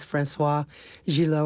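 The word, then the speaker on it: Françoise Gilot: I mean, you had the right mistress, Francoise Gillot, or whatever her name was. Was that how you say it? – Right.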